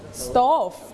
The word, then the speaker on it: stove: It is a stove. – Right? It is a stove. That's right. Okay. Clap your hand for your friend. Good job. Okay. Everyone, you have to listen first. – Stove. – One more time. – Stove. – Stove. Okay. Don't forget to pronounce fur. Okay. ตัว ve ออกเสียง fur